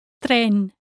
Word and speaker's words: tren: The final word in this lesson is tren. Train. Tren. Practice saying the syllable T-R-E. Tre. Tre. When saying this word, tren, make sure you don't add any vowel sounds after the final N.